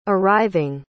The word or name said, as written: arriving